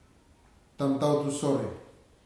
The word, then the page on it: tamtautu sore: futuna aniwa